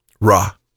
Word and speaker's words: Ra: Ra.